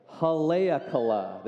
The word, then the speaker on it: Haleakala: I got to say it right, Haleakala.